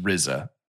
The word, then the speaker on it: RZA: It's pronounced RZA.